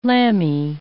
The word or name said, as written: lamby